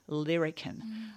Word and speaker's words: larrikin: So they kind of strung these together, and this seems to be the source of larrikin. And kids from Fitzroy of that era said... It was never pronounced larrikin. It was always lyrican, and it just turned into larrikin once it turned into print.